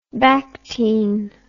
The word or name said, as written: bactine